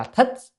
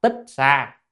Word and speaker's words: Tích Sa: tích sa tạng là còn cái chữ này mà tra từ điển có khi nó ra là thích, nhưng mà thường đọc tích sa,